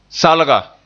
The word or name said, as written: salga